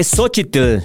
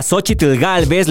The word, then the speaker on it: Xóchitl: Si ponemos atención, prácticamente en todos lados a Xochitl Galvez la pronuncian como Xochitl Galvez, sin la terminación TL. Incluso hasta ella lo pronuncia así. Sin embargo, si analizamos otras palabras que también terminan con TL, como Nezahualcóyotl, Opocatepetl, Nahuatl, Iscoatl y Quetzalcoatl, en todas ellas se pronuncia TL al final. Yo soy de los que dice que se pronuncia Xochitl. Sin embargo, repito, en todos lados le dicen Xochitl.